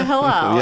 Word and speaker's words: Hillel: Got my Hillel here. – The matzah sandwich is the Hillel. Yeah. I mean, and really nice pronunciation.